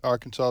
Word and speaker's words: Arkansas: And you can always tell a native Arkansan because this is how they pronounce phonetically their home state. – All right, we're ready. – A-R-C-A-N-S-A-W. – Arkansas.